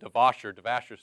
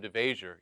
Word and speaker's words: DeVasher: But I went ahead of time, got the seating arranged, made sure they knew my name so they could pronounce it right. I didn't want any of this DeVasher stuff. No, Mr. DeVasher, your table's ready.